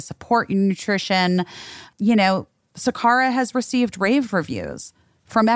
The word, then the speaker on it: Sakara: That's Sakara.